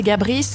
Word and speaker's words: Gabris: Gabris, hein. Avec le S qui se prononce.